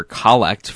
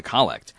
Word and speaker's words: collect: And why is it pronounced collect and not collect? – So you could say collect, but someone might want to try and call you is the problem. – Yeah. – So the reason is that it's coming from the Latin collectio. And the emphasis is on that first syllable because, you know, several years ago, when the translation of the Mass was revised, the name of this prayer was changed.